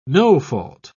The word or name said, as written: no-fault